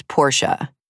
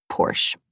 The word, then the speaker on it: Porsche: I was told to pronounce Porsche as Portia. So I did it, but I personally feel like it's Porsche.